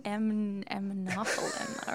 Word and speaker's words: Aminophylline: Aminophylline.